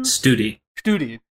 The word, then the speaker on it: Studi: By the way, I looked at West Study. Is that how you pronounce his name? – Studi. – West Studi.